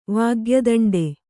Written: ವಾಗ್ಯದಂಡೆ

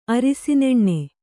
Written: ಅರಿಸಿನೆಣ್ಣೆ

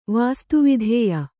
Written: ವಾಸ್ತುವಿದ್ಯೆ

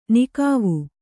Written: ನಿಕಾವು

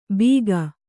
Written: ಬೀಗ

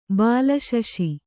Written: ಬಾಲಶಶಿ

